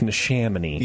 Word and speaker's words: Neshaminy: I think you pronounce it how it looks. It's Neshaminy.